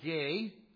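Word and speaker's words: ge (γῆ): It is not the Greek word ge, from which we get geology, G-E. Only the E is pronounced as a long A.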